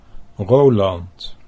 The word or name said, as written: Roland